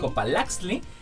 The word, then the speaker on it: Copalaxtli: Y bueno, los chicos, este, desarrollaron un proyecto que se llama Copalaxli, ¿lo dije bien? Sí. Copalaxtli